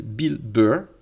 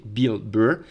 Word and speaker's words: Bill Burr: Ceci étant, en regardant ces humoristes américains, euh, je suis tombé sur un humoriste américain que j'adore qui s'appelle Bill Burr. Euh, je le prononce à l'anglaise parce que puisque c'est un nom anglais, je le prononce avec un accent anglais. Bill Burr, il est américain.